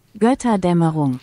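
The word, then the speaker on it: Götterdämmerung: Gotterdammerung.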